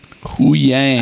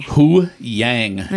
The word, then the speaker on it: Huyang: Hu-Wang. – Huyang. – Oh, it's Huyang. – Huyang. H U Y A N G. Read your paperwork.